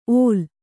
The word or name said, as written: ಓಲ್